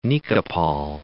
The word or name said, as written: Nikopol